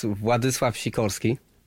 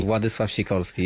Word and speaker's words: Władysław Sikorski: So correct is Władysław Sikorski. – Władysław Sikorski. – Władysław Sikorski, yes.